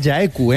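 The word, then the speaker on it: Jaecoo: una auténtica maravilla de los queridos Omoda y Jaeku, que se pronuncia Jaeku, ¿eh? – Sí, sí.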